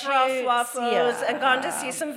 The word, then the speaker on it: stroopwafels: Stroopwafels.